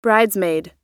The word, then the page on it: bridesmaid: American English